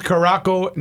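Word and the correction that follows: Curaçao: Netherlands. – Curacao.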